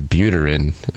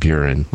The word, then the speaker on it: Buterin: His last name is Buterin, not Buren, like I mispronounced.